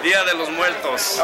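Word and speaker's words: Día de los Muertos: It's a Dos de Muertos ride. How do you pronounce it, really? – Dia de los Muertos.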